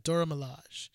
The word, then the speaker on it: Dora Milaje: Yeah, Dora Milaje.